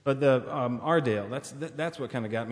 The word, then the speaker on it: Aredale: it's not your Dale, it's not my Dale, it's Ardale, because the town is spelled A-R-E-D-A-L-E, and so it's kind of a pronunciation guide because so many people pronounce it Airedale. – Airedale. – But it's Ardale. – Okay, okay. Anyway. Not like the dog.